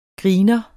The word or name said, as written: griner